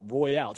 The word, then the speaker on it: Royale: Andre the Giant Battle Royale. It's pronounced Royale, I believe. – Ah, you are correct. Like a Royale with cheese.